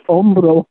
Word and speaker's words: hombro: In Spanish, it's hombro. – Hombro, H-O-M-B-R-O. – Hombro. – Almost like hombre, but it's hombro. – Silent H. Hombro.